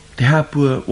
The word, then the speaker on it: Te Hāpua: Te Hāpua, once again, is a name that's been altered to make its pronunciation easier. Originally, it was Te Hōpua, the cove, the sheltering place of canoes.